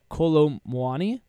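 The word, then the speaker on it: Kolo Muani: So, if any of you guys have a better pronunciation of Colomwani? – I think you got it. I think you nailed it.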